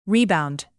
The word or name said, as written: rebound